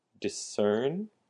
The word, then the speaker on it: discern: Discern and distinguish. So discern has the emphasis on the second syllable. Discern.